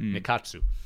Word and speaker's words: Nikatsu: That's not just me mispronouncing it. I really keep thinking the A is an I every time I think of their name. But Nikatsu.